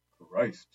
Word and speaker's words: Christ: Christ, said Thurston, who pronounced it to rhyme with floor joist.